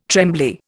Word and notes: trembly: Indian

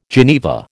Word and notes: geneva: American English

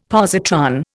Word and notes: positron: Indian

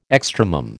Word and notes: extremum: American English

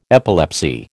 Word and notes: epilepsy: American English

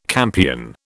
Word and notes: campion: British English

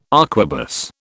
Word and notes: arquebus: British English

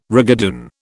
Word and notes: rigadoon: British English

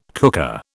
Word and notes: cooker: British English

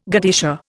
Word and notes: godetia: Indian

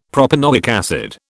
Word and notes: propanoic acid: British English

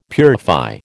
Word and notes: purify: American English

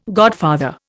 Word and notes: godfather: Indian

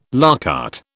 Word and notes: à la carte: British English

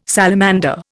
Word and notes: salamander: Indian

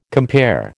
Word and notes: compare: American English

